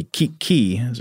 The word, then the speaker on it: kiki: There is a word in, and I actually don't know how to pronounce this language, H-O-K-K-I-E-N. Is that Hokkien, maybe? Or maybe Hokkien? Joe continues, in that language, kiki, pronounced with a hard stop after each syllable, so I guess that'd be kiki or something, commonly used to indicate that an edge is jagged or uneven.